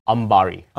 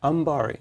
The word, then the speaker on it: Ambari: Ambari. – Ambari.